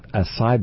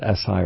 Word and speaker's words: acai: Some call it acai berry. You can pronounce it acai or acai.